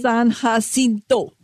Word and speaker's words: San Jacinto: San Jacinto.